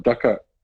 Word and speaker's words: Dochart: What's the correct pronunciation? Can we just get that? – Oh, the stress and the pressure. I'm probably going to get this wrong as well, but I would call it Dochart.